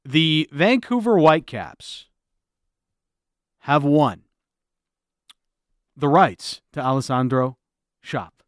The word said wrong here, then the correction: Schopf: Schopp? I'm really having trouble with the name. I don't know why. Like, we have the proper pronunciation back. – We have it. – It's Schopf, isn't it? – Schopf.